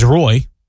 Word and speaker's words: Roy: They didn't emphasize the oi. Roy. – When did you start doing it? – I don't know. That is my name is Roy. It's just, I mean, that is the correct pronunciation.